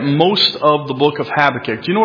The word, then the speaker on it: Habakkuk: Do you say Habakkuk or Habakkuk? Habakkuk, okay. It just depends what syllable you put the emphasis on, I guess.